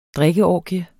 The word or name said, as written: drikkeorgie